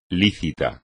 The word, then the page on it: lícito: Spanish